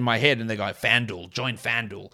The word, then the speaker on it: FanDuel: And I realize that, I know in America you don't pronounce it that way. You call it FanDuel. Duel. That sounds so stupid to me. I can't get my mouth to do it. If I say D-U-E, it's due.